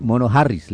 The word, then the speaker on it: Jariz: Pero se escribe Mono Jariz, pero es Mono Harris, le dicen.